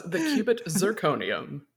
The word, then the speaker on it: The Quibit Zirconium: The Quibit Zirconium. Did I pronounce that well? – Close. The Quibit Zirconium.